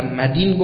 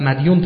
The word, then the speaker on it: مدین: دان یدینو گفته میشود، دائن و مدین گفته میشه که به اشتباه و غلط مدیون تلفظ میکنیم.